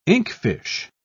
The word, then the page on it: inkfish: British English